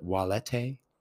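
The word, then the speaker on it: valete: Wale in Latin, which is directed at a singular person saying goodbye, but walete, which is starts with a V, but in Latin, V's are pronounced as W's.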